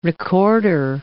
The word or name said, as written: recorder